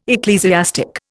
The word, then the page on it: ecclesiastic: Indian